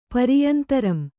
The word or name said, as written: ಪರಿಯಂತರಂ